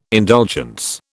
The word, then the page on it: indulgence: British English